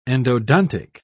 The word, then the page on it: endodontic: British English